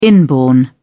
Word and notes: inborn: British English

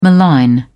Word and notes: malign: British English

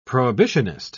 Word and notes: prohibitionist: British English

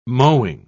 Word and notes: mowing: British English

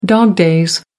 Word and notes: dog-days: American English

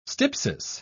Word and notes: stypsis: British English

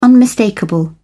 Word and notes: unmistakable: British English

